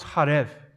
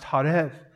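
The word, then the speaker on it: harev (חָרֵב): The Hebrew word for ruin is used to describe the temple in verse 4, and it's pronounced harev in Hebrew. The word for ruin used to describe the temple is pronounced harev, but the word used for drought in verse 11 to describe the conditions of the environment that's around them is pronounced horev. Horev.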